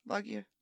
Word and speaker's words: Loggia: Loggia.